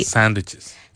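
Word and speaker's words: sandwiches: Sándwiches. – Sándwiches. Sándwiches. La W desaparece por completo, se convierte en una U. Entonces, lo que decimos es sándwiches.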